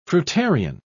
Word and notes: fruitarian: British English

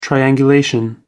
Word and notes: triangulation: British English

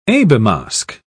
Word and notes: abelmosk: British English

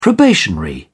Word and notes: probationary: English; British English

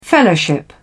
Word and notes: fellowship: British English

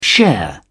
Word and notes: share: British English

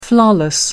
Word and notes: flawless: American English

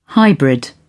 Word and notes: hybrid: British English

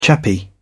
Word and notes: chappie: British English

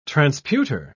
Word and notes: transputer: British English

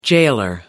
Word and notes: gaoler: American English

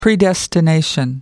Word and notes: predestination: American English

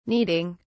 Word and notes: needing: British English

